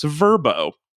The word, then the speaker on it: VRBO: which apparently they have spent a fortune to convince us to pronounce Verbo. – I think it actually always was supposed to be pronounced that way.